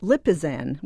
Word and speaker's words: Lipizzan: Well, we've also got Herman's Royal Lipizzan Stallions, and it's pronounced Lipizzan, which almost sounds like an over-the-counter decongestant.